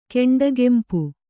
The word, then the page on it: ಕೆಂಡಗೆಂಪು: Kannada